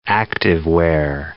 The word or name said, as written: activewear